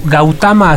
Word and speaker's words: Gautama: Gautama, así es como se pronuncia.